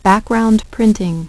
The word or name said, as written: background printing